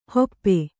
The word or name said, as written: ಹಪ್ಪು